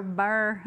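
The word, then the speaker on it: Be'er (באר): It's beer. Okay, it is a place where living water is beer. Okay, but it's actually pronounced Be'er, so or Bur.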